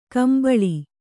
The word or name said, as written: ಕುಂಬಳಿ